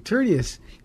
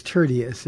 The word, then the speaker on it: Tertius: Tertius is it's Tertius is is the pronunciation, um, and he's a remarkable story.